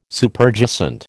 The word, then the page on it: Superjacent: American English